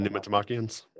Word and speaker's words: Pneumatomachians: I typically say pneumatomachians. Like, I'm putting the emphasis on the second.